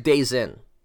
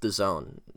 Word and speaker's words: DAZN: You know, back to the DAZN, and if you guys aren't really that familiar with the zone, it's spelled D-A-Z-N, all in caps. It looks like Dazin or Dazin, but it's pronounced the zone.